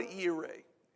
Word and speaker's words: Jireh: In the King James, it says this, and in the Hebrew, the name of that place was Jehovah Jireh, or Jehovah Yireh, if you're trying to pronounce it right with an English accent.